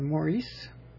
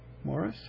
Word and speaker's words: Maurice: Released in November 1978 by the Bee Gees, sung by Barry Gibb and his two brothers, Robin and Maurice, or Morris, however you want to pronounce it, I guess.